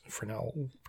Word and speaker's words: Fresnel: And nowadays it's just basically in any kind of projector is going to use a Fresnel lens, Fresnel, one of those, Fresnel, Fresnel, something like that. But the S is silent.